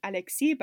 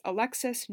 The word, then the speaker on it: Alexis: She actually specified that it's pronounced like the English way, because when you read Alexis in French, it's often Alexis, but she is Alexis Normand.